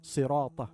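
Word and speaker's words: sirata (صراط): Correction: ensure correct pronunciation of sirat, sirata, as sad should be pronounced with a heavy sound.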